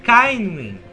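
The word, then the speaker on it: Ceinwyn: Se pronuncia Kainwin.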